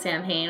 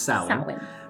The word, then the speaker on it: Samhain: And they were like, please do not pronounce it Samhain or Irish people will literally fucking spit on you. Like, don't do that. – It irks my ears when people who don't know pronounce it Samhain. It's pronounced Samhain.